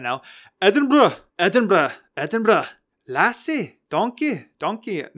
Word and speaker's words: Edinburgh: So I wanted to mix it up on today's episode and talk about my good old city of Edinburgh.